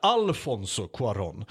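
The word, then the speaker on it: Alfonso Cuarón: Eh, regisserad av, nu ska vi se om jag uttalar hans namn här, Afonso Cuaron. – Jajamän. – Eller Alfonso Cuaron.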